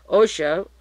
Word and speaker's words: Ochoa: He was closely associated with Pablo Escobar, Jorge is pronounced Ochoa, O-C-H-O-A, and Fabio O-C-H-O-A, Ochoa, who are brothers.